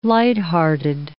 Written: lighthearted

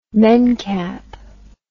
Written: mencap

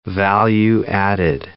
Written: value-added

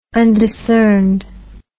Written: undiscerned

